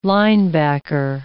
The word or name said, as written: linebacker